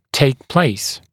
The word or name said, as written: take place